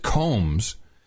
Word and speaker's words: Calmes: And he's he pronounces it Combs, and it took me a while to find out who he's talking about.